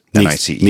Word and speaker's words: Nice: He's in an airport in, in Nice. I don't know how you pronounce it. – N I C E.